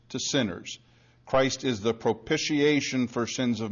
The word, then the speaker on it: propitiation: Generally, you'll hear it said propitiation, like pitch, like pitching a ball, and that's really not right, and Dave Greenwood will tell you this because he's big on pronunciation. It is propitiation, like pish instead of pitch.